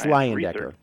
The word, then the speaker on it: Leyendecker: It was written by Emily Kelly, and it's about the Landecker, if I'm saying it right. – I think it's Leyendecker.